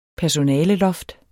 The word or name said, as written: personaleloft